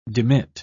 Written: demit

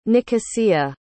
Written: Nicosia

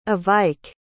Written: Evike

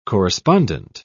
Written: correspondent

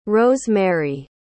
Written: rose-mary

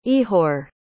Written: Ihor

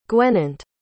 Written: gwennant

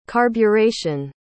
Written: carburetion

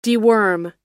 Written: deworm

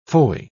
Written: foy

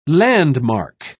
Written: landmark

